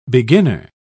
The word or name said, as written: beginner